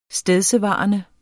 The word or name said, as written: stedsevarende